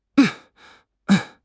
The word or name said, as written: 生态